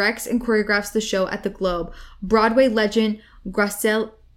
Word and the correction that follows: Graciela Daniele: It looks like Graciela Daniele. – Yeah. – Graciela Daniele.